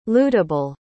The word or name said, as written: lootable